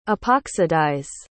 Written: epoxidize

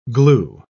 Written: glue

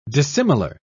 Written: dissimilar

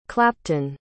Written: clapton

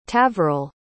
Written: tavaril

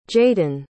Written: jaydon

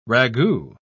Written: ragout

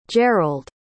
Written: Jerrold

